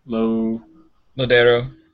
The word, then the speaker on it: Lodeiro: To be honest with you, I think that Lodeiro, or is that how you say his name? – Lodeiro.